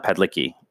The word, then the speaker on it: Padalecki: And the first one was Supernatural star Jared Padalecki. I always get his last name wrong. I'm sorry, guys. – Padalecki? – I think the second A you don't say. – Oh. – Padalecki. – Okay.